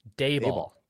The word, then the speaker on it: Daboll: I will say, as a Cowboys fan, Dabble, how do you pronounce that? – Brian Daboll. – Daboll.